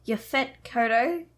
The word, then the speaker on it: Yaphet Kotto: Okay, so according to Yaphet Kotto, I think I'm pronouncing that right. – That sounds good.